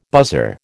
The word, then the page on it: buzzer: American English